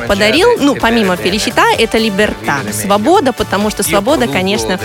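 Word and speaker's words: Libertà: Вы знаете, мне повезло, и в своей жизни я исполнил три композиции, которые, ударение в которые заканчиваются на «а», и ударение падает на эту последнюю «а». Это «Феличита», «Счастье», «Либерта», «Свобода» и «Чисара», «Будущее».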